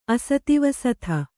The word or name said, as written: ಅಸ್ತಾವಸ್ತೆ